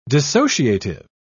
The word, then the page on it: dissociative: British English